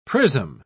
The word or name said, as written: prism